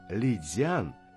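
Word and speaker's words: Лицянь (漓江): Надо обратить внимание на то, что в данном случае в слове Лицянь Ли произносится во втором тоне, дзян в первом тоне.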